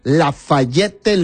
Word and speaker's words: Lafayette: Él me dijo de que así estaba bien pronunciado. Lafayette, Luisiana.